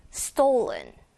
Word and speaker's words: stolen: stolen, stolen, 른 앞으로 나오지 않고 뒤로 먹어요.